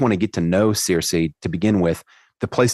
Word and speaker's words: Circe: So Circe is not spelled like it sounds unless you're familiar with um, Homer's Odyssey. It's spelled C I R C E.